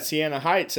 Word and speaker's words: Siena Heights: You guys had Siena Heights as well.